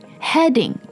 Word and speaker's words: heading: H-I-D-I-N-G. Heading. H-E-A-D-I-N-G. 所以它里面其实这两个单词之间发音的不同是在元音的不同上面。